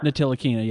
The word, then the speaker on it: Ntilikina: Probably, and I probably can't even say his name right, but Frank Ntilikina. – Ntilikina, yeah.